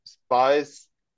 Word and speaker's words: Spies: And the thing is, you know, Spies, when you translate it to German or also in English, you can say it like it's an English name or it's a German name. But I prefer the English way because then we can take it like James Bond 007 and I'm Max Spies, you know, the spy 007.